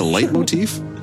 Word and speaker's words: leitmotif: A leitmotif? – Yeah.